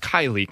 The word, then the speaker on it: Kiley: I feel really bad about it because people mispronounce my last name is not hard. It's Kiley, K-I-L-E-Y, but people call it Kyle. They call it all different things.